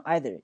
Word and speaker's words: either: OK，所以这是两者和三者啊，两者的肯定有个 both，有个 all，两者的否定有个叫 neither，有个叫 none，还有最后一组，一个叫 each，一个叫 either，对吧？E-I-T-H-E-R 这个单词两种读音，你既可以读成 either，也可以读成 either，都无所谓啊，美国人两种读法。